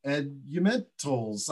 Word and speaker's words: Eldementals: We've got Robin Mansfield with Aging Network Services, and we are about to see Eldementals. And tell me how I pronounce that. – It's Eldementals. It's Eldementals. Yeah, or fundamentals of.